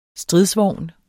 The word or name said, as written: STRIDSVOGN